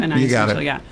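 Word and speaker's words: Anaïs Mitchell: Anais Mitchell, yeah.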